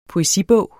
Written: poesibog

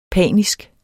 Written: panisk

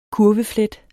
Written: kurveflet

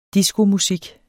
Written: diskomusik

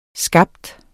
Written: skabe

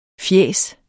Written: fjæs

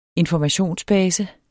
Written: informationsbase

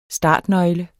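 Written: STARTNØGLE

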